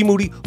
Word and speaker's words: Moody: G Moody, whose last name rhymes with duty.